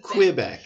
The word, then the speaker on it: Quebec: 1636, French Jesuit Isaac Yogues arrives in Quebec or Quebec or Quebec. – I heard it always.